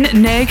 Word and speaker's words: née: What's the nay before? What? How do you say that? – Née.